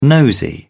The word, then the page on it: nosy: British English